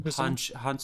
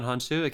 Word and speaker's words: Hounsou: But the Helen Mirren, Lucy Liu, and Jimon, how do you pronounce his last name? – Hansu. Jimon Hansu, Academy Award nominee. – Jimon Hansu.